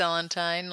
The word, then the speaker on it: Sellentine: Yeah, I say Sellentine, like Valentine, whatever.